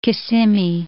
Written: Kissimmee

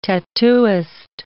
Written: tattooist

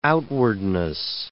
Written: outwardness

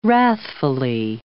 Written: wrathfully

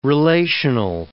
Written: relational

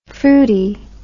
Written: froody

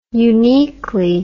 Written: uniquely

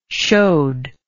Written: shoad

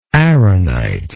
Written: arenite

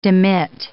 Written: demit

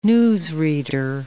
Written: newsreader

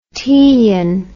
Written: Teian

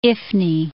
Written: Ifni

